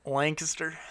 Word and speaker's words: Lancaster: You gonna say it right? – Lancaster. – There you go.